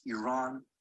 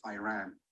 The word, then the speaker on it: Iran: Say you support the Iranian people, but you're pronouncing our country's name wrong. Our country is named Iran, not Iran.